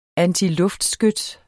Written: antiluftskyts

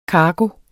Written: kargo

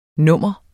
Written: nummer

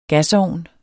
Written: GASOVN

